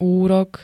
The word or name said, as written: úrok